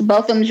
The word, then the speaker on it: Botham: Yeah, and it was just like that narrative with, how do you say his name, Botham? – Mm-hmm. Yes, Botham John.